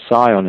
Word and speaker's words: scion: Now, in Masonic architecture, a graft is the place of junction of stock and scion, that's spelled S-C-I-O-N. Now, we have to remember the prior of scion in this mystery somewhere. It's exactly the same sounding word. And this S-C-I-O-N, scion, comes from the old French S-I-O-N, which is exactly how we see it in the prior of scion.